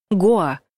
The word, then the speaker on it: Гоа: Во-первых, ударение ставят на «а», хотя все словари единодушно твердят, что правильно произносить «Гоа».